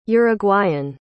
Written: uruguayan

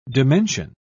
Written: dimension

